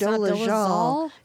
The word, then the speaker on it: Dolezal: I finally learned how to pronounce her last name. – It's Dolezal.